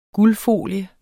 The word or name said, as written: guldfolie